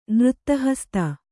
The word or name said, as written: ನೃತ್ತಹಸ್ತ